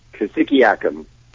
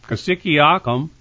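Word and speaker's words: Cassiciacum: Is that how you say it? – Kosciuszko. – Kosciuszko.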